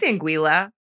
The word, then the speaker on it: Anguilla: Did I say that right? Anguilla? I said it right. – It's Anguilla. – Anguilla. Anguilla, Anguilla, tomato, tomato.